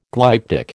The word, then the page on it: glyptic: American English